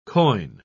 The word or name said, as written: quoin